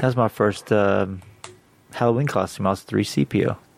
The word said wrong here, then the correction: C-3PO: Isn't it C-3PO? – I don't know. When I was a kid, I said it wrong.